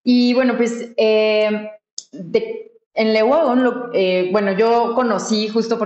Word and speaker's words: Le Wagon: le pregunté a Mariana cuál era la pronunciación correcta, entonces me referiré a esta empresa todo durante todo el episodio como Leguagón.